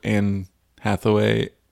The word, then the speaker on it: Anne Hathaway: And so it's the one with Anne Hathaway.